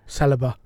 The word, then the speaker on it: Saliba: Saliba.